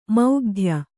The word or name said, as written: ಮೌಗ್ಧ್ಯ